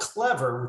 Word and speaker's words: Clevr: Um, but we're called Clevr, which is spelled C L E V R, because we also have virtual reality uh, stations as well.